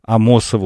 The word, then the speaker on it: Амосова: Подозреваю, что слушатель с именем Михаил пишет мне о том, что в имени известного кардиохирурга и, можно сказать, евангелиста здорового образа жизни Николая Амосова ударение ставится на второй слог.